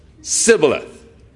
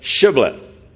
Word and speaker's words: shibboleth: Now, so it was among the Ephraimites, they could not pronounce the SH sound, the sh. But it was a s, sibboleth, instead of shibboleth.